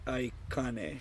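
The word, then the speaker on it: aikāne: Pronounce this for me. I need a translator. All right, right here. Akane? Is that how you say it? – Moi Ikane.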